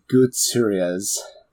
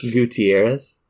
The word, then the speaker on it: Gutierrez: Yeah, and that's Froy Gutierrez. I'm sorry. I know I said that wrong. But Mike... – Gutierrez? – Thank you.